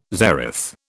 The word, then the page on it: zeroth: British English